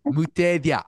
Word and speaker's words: Mutethya: Mutethya.